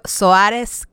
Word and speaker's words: Soares: Mario Suárez Capital, se escribe S-O-A-R-E-S Capital, ¿ok? Así como se escucha.